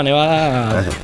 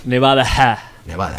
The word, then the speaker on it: Nevadah: Es igual, se llama Nevada tal cual. – Nevada.